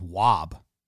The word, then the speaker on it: Juab: Someone else says it's just Wob. The Wob Wasps. Like Moab. Moab.